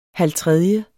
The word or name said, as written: halvtredje